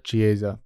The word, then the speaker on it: Chiesa: Him versus Michael, how do you say his last Chiesa? – Name? Chiesa. Michael Chiesa versus Kevin Holland. 18-6 for Michael. 24-9 for Kevin. 24 wins. – Listen, I've said what I've said about Chiesa,